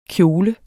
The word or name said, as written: kjole